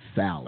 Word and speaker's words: salmon: See, one of the ones that pronounces the L in salmon. – Salmon.